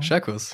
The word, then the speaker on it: Szerkus: Scherkus. Scherkus, alles klar. Das SZ ist ein SCH.